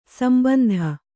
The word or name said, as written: ಸಂಬಂಧಿ